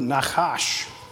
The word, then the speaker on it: Nachash: When you see the word Nachash at the beginning, I'm pronouncing that in the Hebrew way. I'm doing that on purpose. That's how the Hebrew would say it.